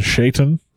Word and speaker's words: Shaitan: And then you have, I'm going to use a slightly different pronunciation, Shaitan.